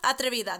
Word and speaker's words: Atrevida: It's Travevida? No. Atrevida? Atrevida. That sounds about better.